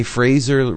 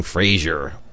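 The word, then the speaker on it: Frazer: He told me Fraser rhymes with laser, which is cool because I was saying Fraser.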